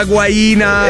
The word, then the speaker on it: guaina: Vaglielo spiegare che è legato alla guaina. – E che si pronuncia esattamente così, la guaina. Vedi che neanche l'intelligenza artificiale sa che si pronuncia guaina.